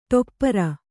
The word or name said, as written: ಟೆಪ್ಪರ